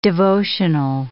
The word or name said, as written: devotional